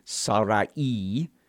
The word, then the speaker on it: Sarai: Now, Sarai, who is also a daughter of Terah, her name, which is actually Sarai, is the way we should pronounce it to get closer to the Hebrew.